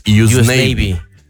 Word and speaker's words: Usnavi: Y pues en realidad se pronunciaría en inglés US Navy. pero pues en español lo lees y dices, Usnavi.